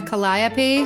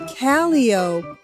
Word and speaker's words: calliope: But the dead giveaway is that a circus person would call that steam organ a calio. – Not a calliope? – A calio. So it's spelled the same, but it's pronounced calio.